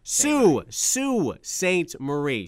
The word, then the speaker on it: Sault Ste. Marie: Am I saying this right? Say it for me. Sault Ste. Marie, is that what it is? – Sault Ste. Marie. – Sault, thank you for that. Why is it spelled S-A-U-L-T?